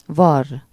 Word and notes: naaien: /ˈnaːjə(n)/

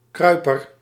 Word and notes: sycophant: American English; /ˈsɪ.kə.fænt/; /ˈsɪ.kə.fənt/

